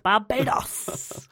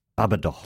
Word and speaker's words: Barbados: He went to Barbados. – I think it's pronounced Barbados. – Thank you. I always get that one wrong.